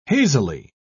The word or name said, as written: hazily